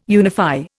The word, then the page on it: unify: Indian